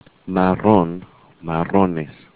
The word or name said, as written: marrón